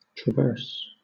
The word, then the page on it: traverse: British English